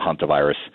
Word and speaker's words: hantavirus: I even saying it correctly? – Hanta. You are. And I think you know it's pronounced both ways. So we call it hantavirus.